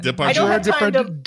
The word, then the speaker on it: Depardieu: Depardieu.